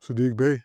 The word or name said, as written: Saddiq Bey